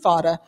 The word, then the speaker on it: FADA: F-A-D-A?